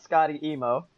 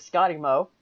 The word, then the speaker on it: Scottye: Which, if anyone follows me on Twitter, my name is not Scotty emo. It is Scotty Mo. The problem is, I spell my name very weird. I spell it S-E-O-T-T-Y-E. Add more at the end, you get Scotty Mo.